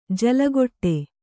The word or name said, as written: ಜಲಘಡಿ